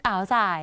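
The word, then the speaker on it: áo dài: อ๋าวสาย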